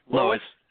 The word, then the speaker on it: Lowisz: Lois. – Okay. – You got L-O-W. I'm going to spell it for everybody. L-O-W-I-S-Z.